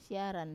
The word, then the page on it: siaran: wamesa